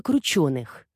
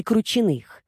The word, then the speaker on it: Крученых: «С того, Соня, что фамилия у человека Крученых, а не Крученых, с ударением на «и».